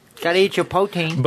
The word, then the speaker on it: poutine: I've also heard it pronounced poutine. – Well, that's incorrect. That's incorrect. – But no, you're planning to say poutine as well, though. – It's poutine.